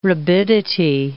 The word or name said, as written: rabidity